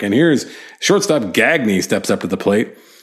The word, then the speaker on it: Gagne: Whenever his last name was Gagne, G-A-G-N-E. But nope, his name was Gagne. That's how he pronounced it.